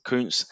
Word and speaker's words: Kuntz: I will pronounce it Kuntz. Yep. I think that's actually correct for once as well.